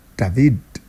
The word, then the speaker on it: David: Yes, I'm calling it David because the guy is Spanish and you have to pronounce it the Spanish way. David.